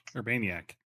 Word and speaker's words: Urbaniak: And James Urban, I can't say his name, the venture guy, James Urbaniak. – Urbaniak? – Urbaniak. – Urbaniak. – It's like, I have to remember Animaniacs, and then I remember how to pronounce his name.